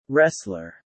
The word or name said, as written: wrestler